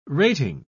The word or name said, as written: rating